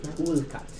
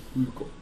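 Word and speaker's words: Ul'cot: Mrs. Wilcott. – Ulcott. – Ulcott. It's spelled UL apostrophe COT. – Ulcott.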